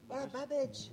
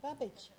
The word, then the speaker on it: Babbage: Babbage. Babbage.